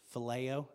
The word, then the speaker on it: phileo: There's phileo. Is that right? Yeah.